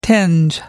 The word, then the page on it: tinge: American English